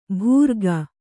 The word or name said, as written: ಭೂರ್ಗ